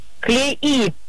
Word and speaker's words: клеи: Внимание. Клеи на «и» или клей на «е»? – Клеи на «и».